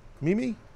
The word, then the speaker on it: Mimi: How do you say her name? Mimi? M-I-M-I. Mimi? – Mimi.